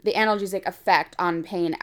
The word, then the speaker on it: analgesic: This was 85 controlled trials, so almost, you know, around 3,500 people, and they found that hypnosis produced a moderate to large analgesic. Am I saying that right? – Yeah.